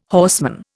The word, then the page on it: horseman: Indian